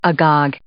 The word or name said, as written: agog